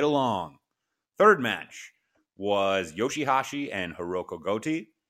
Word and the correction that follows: Goto: Wow, that was some really bad pronunciation. I apologize. Hiroko, just, it's Goto.